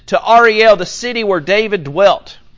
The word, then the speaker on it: Ariel: And it starts out, Woe unto Ariel. And, and that's the correct pronunciation. Uh, we would look at it and say Ariel, right? Uh, like the Little Mermaid. Uh, we would look at it and say Ariel. Um, but woe to Ariel.